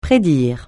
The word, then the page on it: prédire: francais